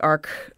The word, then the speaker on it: Arch: It's spelled like arch, but it's pronounced like arc.